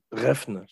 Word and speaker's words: Refn: Il y a un grand fan du film qui est Nicolas Reft, euh, Reft, Refn, j'arrive jamais à prononcer nom. Voilà, bravo.